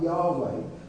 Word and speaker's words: Yahweh: In older translations, it'll be Jehovah. Nowadays, the scholars think it's pronounced more like Yahweh.